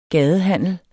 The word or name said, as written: gadehandel